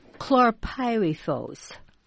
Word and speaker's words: Chlorpyrifos: Chlorpyrifos. – Chlorpyrifos.